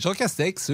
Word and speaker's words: Castex: Oui, presque, mais Jean Castex, oui. J'avais il y avait une petite prononciation qui m'avait amusé, mais vous l'avez bien dit.